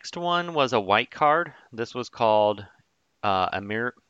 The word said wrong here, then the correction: Emeria: How do you pronounce it? – Emeria. – Emeria. Excuse me.